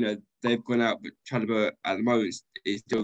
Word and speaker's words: Chalobah: Trevor Chalobah, yeah.